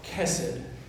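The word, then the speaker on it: chesed (חֶסֶד): It's called chesed.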